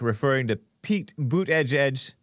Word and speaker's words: Buttigieg: I don't know how he pronounces the name, but that's how Trump pronounces it. And that's how he's said to pronounce it. Buttigieg.